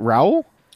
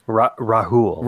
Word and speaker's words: Rahul: I don't know how they would have done it, but I mean, we have is it Raul? – Rahul. – Rahul. – They actually pronounce the H in it. I think Americanized it would have been Raul.